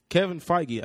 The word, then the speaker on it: Feige: Kevin Feige. I said that right. – Yeah, right? – You did.